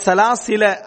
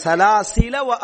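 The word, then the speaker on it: சலாசில (سلاسلا): அந்த வட்டம் என்னன்னா நீங்க சேர்க்கிற டைம்ல இதை உச்சரிக்காதீங்க, சேர்க்கிற டைம்ல இதை உச்சரிக்காதீங்க. சலாசில அகலாலன் சலாசில, அப்ப அந்த அலிப்பை நம்ம உச்சரிக்கல பாருங்க, சலாசில நிறுத்தினீங்கன்னா சலாசிலா அப்படின்ட்டு இன்ன அத்தது நாளில் காஃபிரி சலாசிலா அப்படின்னு என்ன செய்வோம், நம்ம நிறுத்துவோம்.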